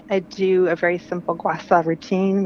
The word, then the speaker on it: gua sha: Gua sha.